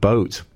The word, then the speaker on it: Both: That's been called, it's actually B-O-T-H, but I suppose you pronounce it boat.